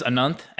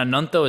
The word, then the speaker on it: Ananto: So my name is Anant, and Ananto is actually how you pronounce my name on...